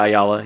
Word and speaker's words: Ayala: We have like Leo Williams of Vita Bayali. I always forget how to say her last name. – Vita Ayala.